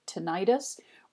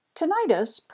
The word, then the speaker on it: tinnitus: So we'll start with the Harvard Health Publishing, Harvard Medical School, Harvard Women's Health Watch, and the article is titled Tinnitus, Ringing in the Ears and What to Do About It. And this was updated on April 8th, 2020. Tinnitus, pronounced tinnitus or tinnitus, is sound in the head with no external source.